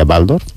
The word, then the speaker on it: Waldorf: Estar en una escuela Waldorf, que la pronunciación correcta sería Waldorf, ¿no es verdad? – Sí.